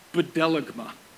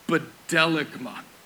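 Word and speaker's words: Bedelagma (βδέλυγμα): In the language the New Testament was written in Greek, this is an ugly word. Bedelagma. Bedelagma. That's actually how it's pronounced.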